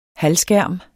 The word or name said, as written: halvskærm